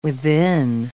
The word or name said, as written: within